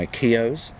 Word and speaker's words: Keogh's: Goose has spotted steak and Guinness flavour uh, being launched in Ireland by Keogh's. – Okay. – Is that the right pronunciation? You're Irish. Yes, yes. Very good.